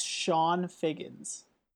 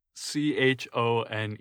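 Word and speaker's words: Chone Figgins: It's C H O N E. F I, G G I N S. Right. – It's like Chon. I thought it was Chon. – It is pronounced Sean.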